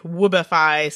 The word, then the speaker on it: woobify: woobify said bad dude. Because he's cute. – Woob? How do you spell it? W-O-B? – W-O-O-B-I-F-Y.